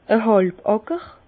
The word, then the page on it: a hàlbe Àcker: Bas-Rhin; Herrlisheim